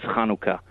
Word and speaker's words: Hanukkah: Most people say Hanukkah, but the way you pronounce it is Hanukkah.